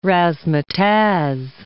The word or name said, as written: razzmatazz